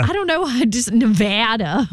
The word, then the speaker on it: Nevada: You pronounce Nevada. It's Nevada. – No, it is. – Since I grew up there, it drives us crazy.